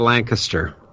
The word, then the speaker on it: Lancaster: Nobody says Lancaster correctly. – Yeah, no, that I screwed up, I know, because I say it the way the rest of the United States says it outside of Pennsylvania, with the emphasis on Lang, not Caster.